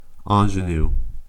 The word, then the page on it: ingenue: American English